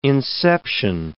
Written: inception

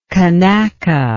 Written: kanaka

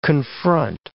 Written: confront

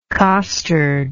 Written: costard

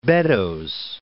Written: Beddoes

